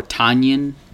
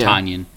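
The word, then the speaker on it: Tynion: Because I think they met him at C2E2 or talked to a creator like Donnie that might know, yeah, Tanyan. But I think that's how you pronounce it. I've been saying Tinian the whole time.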